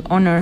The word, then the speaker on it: Honor: Creo que Honor, sí, porque la H es muda en Estados Unidos, Honor.